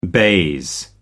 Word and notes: baize: American English

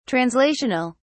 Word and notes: translational: British English

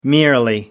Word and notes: merely: American English

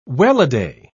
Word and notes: welladay: British English

